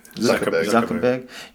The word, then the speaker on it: Zuckerberg: Zuckerberg